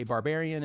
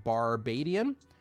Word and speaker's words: Barbadian: Most people m- mispronounce it anyway and say barbarian instead of Barbadian.